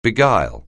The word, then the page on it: beguile: anglais